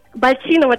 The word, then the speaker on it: Бальчинова: Юль, мы правильно произносим ударение вашей фамилии? Бальчинова? – Бальчинова, да, все верно. – И на «и» Бальчинова, потому что у нас были сомнения. – Да, на «и», все верно.